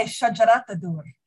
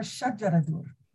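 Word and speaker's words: Shajar al-Durr: And our main character, Shajar Al-Dur, have I pronounced that right? Well, it's funny because we want to say Shajaratur, but medieval Arabic texts, they call her Shajaradur.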